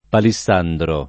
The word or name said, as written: palissandro